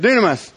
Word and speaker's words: dunamis: Dynamis, I don't know how you say it. Dunamis. Dunamis, yeah, that's what he said.